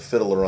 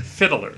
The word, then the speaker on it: Fiddler: Fiddler. It's not fiddler, it's fiddler. – Fiddler. – You're adding an extra syllable. Don't do that.